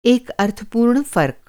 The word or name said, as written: अर्थपूर्ण